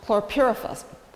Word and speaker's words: chlorpyrifos: is a pesticide pronounced chlorpyrifos.